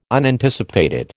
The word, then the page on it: Unanticipated: American English